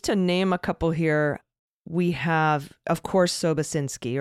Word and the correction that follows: Sobocinski: or Sobachinsky, excuse me, however, uh, I, f- I feel like you pronounce it Sobachinsky. Somebody sent me a correction.